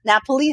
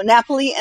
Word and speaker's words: Napoli: Napoli and an Amalfi Coast. Kristen, is that how you say it? – That's how I would say it. I don't speak Italian, but sure. – Okay. So Napoli and Amalfi Coast, Bordeaux, France coming up.